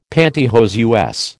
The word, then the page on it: panty hose: American English